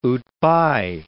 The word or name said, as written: outby